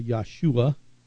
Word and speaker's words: Yahshua: It's pronounced in Hebrew more like a Yahshua.